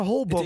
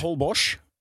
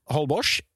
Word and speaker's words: Holbox: Het is Holbosch. Moet je het uitspreken? – Holbosch. – Dat is in de lokale Maya-taal. Is dat Holbosch? – Kunnen de Mexicanen de X niet uitspreken? – Nee, dat kunnen ze niet. Nee. – Nou, wat gek. – Nee, ze kunnen heel veel niet. Maar de X uitspreken is een van de belangrijkste dingen die ze niet kunnen. – Nee, dat heet gewoon Holbosch.